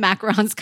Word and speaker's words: macaron: And I know in French it's actually macaron. But macaron? – Well, macarons, whatever, they're very tasty. – It's just not macaroons.